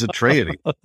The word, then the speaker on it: Atreides: What's the correct way to say Atreides? – Oh, I'd be happy to do that. And well done you. You nailed it on the first try. It is Atreides. Paul and Leto Atreides.